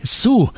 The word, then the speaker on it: Sioux: Also ich spreche es mal deutsch aus, ein Sioux-Indianer namens Boy, der natürlich ganz bewusst im Englischen auch ganz genauso ausgesprochen wird wie die Sue.